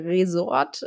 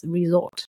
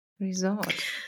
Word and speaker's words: Resort: Ja, das Hotel Resort oder so oder Resort wird oft als Ressort im Deutschen plötzlich ausgesprochen, als ob das als ob das der Fachbereich das Ressort wäre, aber es ist es nicht. Es ist ja das mit einem S, Resort.